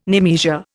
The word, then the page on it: nemesia: Indian